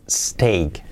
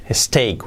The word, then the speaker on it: steak: Un filete de carne que suele ser de ternera, se dice steak. Steak, cuidado, el error típico es decir steak, no, steak, steak, steak.